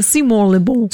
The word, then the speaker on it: Simon Le Bon: So wird er ausgesprochen. – Simon Le Bon. Ich sage immer Simon Le Bon.